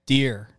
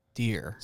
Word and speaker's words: Duerr: How do you say your last name? – Duerr. – Duerr, not Duerr? – As in the animal Duerr.